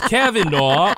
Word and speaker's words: Kavanaugh: Was Cy like, how do I pronounce this name anyway? – Kavanaugh. Everybody knows Kavanaugh.